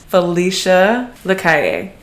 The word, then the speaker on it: Felicia Lacalle: So, without further ado, I'm going to introduce my amazing guest, Felicia Lacalle.